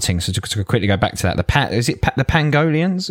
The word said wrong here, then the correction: pangolins: is that you pronounce it? Pangolins? – I say pangolians. – Pangolins? Pangolins? What were you saying? Pangolians or pangolins? – Pangolins.